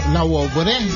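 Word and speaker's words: Labouré: Y también es día de Santa Catalina de la Wobre, la Wobre, la Wobre, porque lleva acento en la E.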